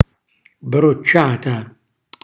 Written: brocciata

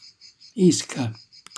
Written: isca

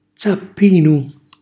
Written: zappinu

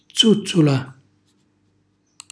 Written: zùzzula

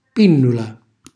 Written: pinnula

